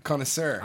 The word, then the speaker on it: connoisseur: Concierge. How do you say it? Beer connoisseur.